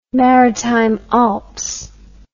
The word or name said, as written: maritime alps